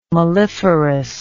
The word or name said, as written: melliferous